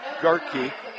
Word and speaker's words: Gaerke: Samantha Garkey, spelled G-A-E-R-K-E, pronounced Garkey, right side hitter, 5'9, a senior.